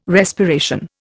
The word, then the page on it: respiration: Indian